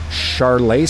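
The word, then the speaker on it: Charolais: It's a Charlet steer. Did I say that right? – Yes.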